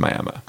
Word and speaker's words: Miami: It's actually spelled exactly like Miami, but pronounced Miami.